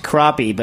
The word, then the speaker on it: crappie: Well, it's like kind of the poorly named – it's pronounced crappie but written crappy.